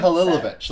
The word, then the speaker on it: Hadzihalilovic: Hadzihalilovic. That's it. Sorry. – You don't say the H's. You don't say H's in French.